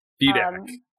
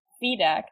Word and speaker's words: Fedak: I don't know how you say his name. I'm sorry, Chris. – Fedak. – Fedak. That sounds right.